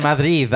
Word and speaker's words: Madrid: In Madrid, there was, shut up. That's how they say it. – In Madrid. – I said it with the D at the end.